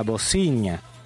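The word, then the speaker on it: Bozinha: Se escribe con B corta, con Z y NHA, Bocinha.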